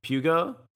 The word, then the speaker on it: Peugeot: Pugo. Peugeot.